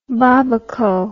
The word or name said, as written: babaco